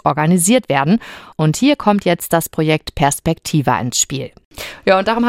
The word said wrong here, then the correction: Perspektiva: Und das Projekt, habe ich das richtig gesagt? – Fast. – Wie sagt man es richtig? – Wir sagen Perspektiva. – Perspektiva.